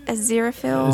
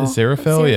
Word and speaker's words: Aziraphale: As Aziraphale. – Aziraphale, yeah.